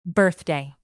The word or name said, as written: birthday